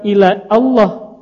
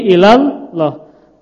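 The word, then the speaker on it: ilallah (إلى الله): Di awal kalimat Allahumma kita baca. Tetapi tatkala dia berada di tengah alifnya, bacanya apa Bu? Ilallah, bukan ila Allah, tapi ilallah. Karena alifnya namanya hamzatul wasal atau alif wasal ya.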